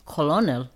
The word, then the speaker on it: colonel: Yeah, see, that one's, I probably shouldn't have put that in because that's just like, you would never know how to say that unless you knew how to say it because that's Colonel.